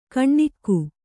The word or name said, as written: ಕಣ್ಣಿಕ್ಕು